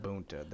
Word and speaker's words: Gamabunta: Gamabunta, the big ass motherfucking toad.